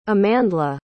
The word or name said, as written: amandla